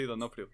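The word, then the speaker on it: D'Onofrio: pero también se dice que el señor Vincent de Nobre, sí, lo pronuncié bien. – Sí, Donofrio, sí.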